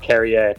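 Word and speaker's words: Käärijä: And it's by an artist, I believe this is how you pronounce it, Kerrier, potentially, JP. Lots of funny A's in there, but I believe his name is pronounced Kerrier.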